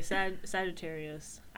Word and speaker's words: Sagittarius: I didn't know how to say this one for the longest time. – Sagittarius. – I mean, I just said that, nigga, but like, I heard people call them Sagittarius terrorists.